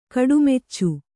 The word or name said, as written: ಕಡುಮೆಚ್ಚು